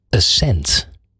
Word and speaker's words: Ascent: Zij zijn samen met hun vriend Bernard Nederlof oprichters van Ascent. – Ja, zeg ik het goed uit? – Ja, dat zeg je goed.